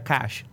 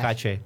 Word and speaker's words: caché: Se pronuncia cash, cash caché. Caché. Ok.